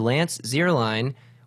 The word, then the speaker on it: Lance Zierlein: It is the second vowel of I-E-E-I is the long vowel, so the long vowel sound. But unfortunately, somewhere along the Kansas farmlands where both of my parents come from, it turned into Zierlein. So it's pronounced like the kicker.